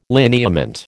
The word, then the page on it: lineament: American English